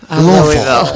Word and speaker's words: Louisville: Louisville. Actually, that's how they say it, Louisville.